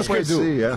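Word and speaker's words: Hüsker Dü: Então se eu errar a pronúncia, tá, vocês me corrijam. Husker Du. – É, tipo Husker Du. É isso aí.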